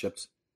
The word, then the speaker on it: Shjips: It's just pronounced Shjips. – It's spelled Shjips. – Spelled with a J.